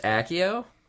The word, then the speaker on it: Accio: Wait, it's pronounced Accio? – Yeah, dude.